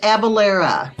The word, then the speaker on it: Avelleyra: It's pronounced Avelleyra.